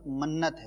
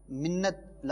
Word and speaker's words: منت: ایوب بھائی کچھ تلفظ کی درستی کے لیے سے میں عرض کر دوں, لفظ منت ہے, منت لفظ نہیں ہے, بلکہ منت کا معنی تو اردو کے اندر منت سماجت وہ الگ ہو جائے گا.